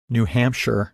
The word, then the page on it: New Hampshire: English